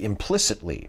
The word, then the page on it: implicitly: American English